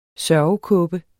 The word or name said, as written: sørgekåbe